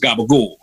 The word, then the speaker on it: capicola: Like, you know, like, you know, it's like kind of how you say, like, you know, like, Capicola is pronounced Gabagool, you know, like, because it's not really pronounced that way. But in America, it's all bastardized.